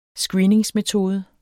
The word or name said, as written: screeningsmetode